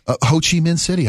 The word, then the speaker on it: Ho Chi Minh City: Ho Chi Minh City.